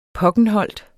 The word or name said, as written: pokkenholt